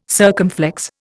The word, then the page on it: circumflex: Indian